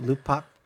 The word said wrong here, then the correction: Kopi Luwak: Oh, Kopi Luwak. – That's it. That's it. – Kopi Luwak.